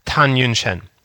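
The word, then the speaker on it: Tan Yunchen (谈允贤): I don't know if you've heard of this doctor, Dr. Tan Xunyan. She's, hold on, fact check myself. I need to make sure I'm pronouncing her name right. Tan Yunchen. Sorry.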